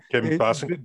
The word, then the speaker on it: Basinger: Kim Basinger. – Basinger.